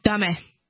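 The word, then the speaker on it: Dame (だめ): Dame! I yell in Japanese, which means bad. Did I pronounce that right? – Dame.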